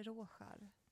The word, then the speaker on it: Rorschach: det är så kul, för att det är som någon form av, heter det Rorschach? Uttalar jag det rätt? – Rorschach-test. – Rorschach.